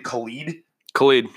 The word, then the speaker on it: Khalid: How do you even pronounce his name? Khalid. – You're right.